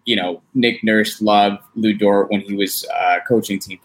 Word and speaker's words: Lu Dort: Look, all I'm saying is that if he's going to be like PJ Tucker or, you know, even a Lugin Stortz, sorry, I don't know if I'm saying his name right, my bad. – Lu Dort.